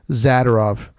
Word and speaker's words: Zadorov: For some reason, everybody calls him Zadorov, which I highly disagree with the pronunciation of his name. We don't say Fedorov, we say Fedorov. So I figure if we say Zadorov, it's not Zadorov.